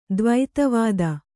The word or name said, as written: ದ್ವೈತವಾದ